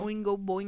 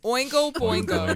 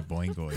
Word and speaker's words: Oingo Boingo: Oingo Boingo. – Wait, oh, you're right. I said that weird. Oingo Boingo. – Oingo Boingo.